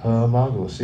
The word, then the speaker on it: homologous: be homologous? Homologous. Yeah, I said it right the first time, having the same relation. Homologous. Like analogous, but homologous.